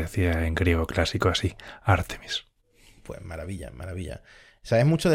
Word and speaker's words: Ártemis: Amigos, se han anunciado por fin los tripulantes de la misión Artemis 2, que también he aprendido que no se pronuncia Artemis. O sea, o dices Artemisa, que es el nombre en castellano, o lo pronuncias Artemis, que es como supuestamente eh, se debe de pronunciar.